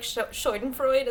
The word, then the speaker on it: Schadenfreude: But you got such like I never know how to pronounce the German word like Schadenfreude.